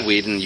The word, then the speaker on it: Whedon: Whedon, you are a fucking prince. – I pronounced that right.